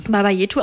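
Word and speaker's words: Baba Yetu: Und aber eins von denen, das hören wir jetzt, das ist Baba Jetu. Richtig? – Baba Jetu. – Baba Jetu ausgesprochen.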